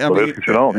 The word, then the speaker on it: Shenault: You told us you were the one that turned us on to that great receiver, Shenault. Is that how you say it? – Shenault, yeah.